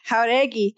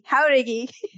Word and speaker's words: Jaruagei: You can have Jaruagei. Jaruagei. Yeah, Jaruagei.